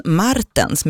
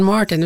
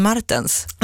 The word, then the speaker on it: Martens: Just det, men nu får vi ta det från början, Glenn Martens med betoning på R-et. Vem är det? – Ja, det är inte Glenn Martin utan Glenn Martens.